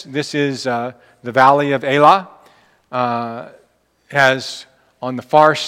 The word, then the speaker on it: Elah: You see the Elah, or the Israelis pronounce that Elah. They call this the Valley of Elah. Elah is how we would probably say it.